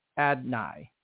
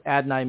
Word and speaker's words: Adonai: Just so everyone knows, that is the correct pronunciation. I know it's spelled Adonai, but he said Adonai. That's how he said to pronounce it.